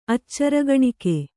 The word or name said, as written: ಆಚಾರಗಾಣಿಕೆ